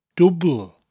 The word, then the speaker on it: dwbwl: Now, double in Welsh is dwbl, dwbl. It's spelt D-W-B-W-L, but we drop the last W in conversation. So instead of saying dwbl with two O sounds, we just say dwbl.